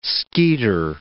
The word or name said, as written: skeeter